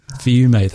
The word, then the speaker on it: Fiume: Fiume.